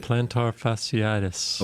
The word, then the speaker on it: plantar fasciitis: Plantar fasciitis.